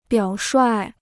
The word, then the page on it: 表率: biǎo shuài